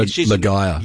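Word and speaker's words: Laga'aia: The good news about this version of Moana, the live-action, is Catherine Ligeia, I hope I said her name right there. – Ligeia. – Yeah, there you go.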